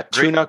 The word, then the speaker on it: Tuna: Yeah, Tuna Christmas. A Tuna Christmas. – It's actually tuna. – Like tuna. Yeah, like the canned fish.